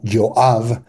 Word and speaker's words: Joab: De hecho, Joab, que se escribe en el mundo latino y se pronuncia como Joab,